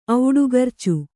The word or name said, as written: ಔಡುಗರ್ಚು